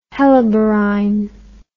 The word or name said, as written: helleborine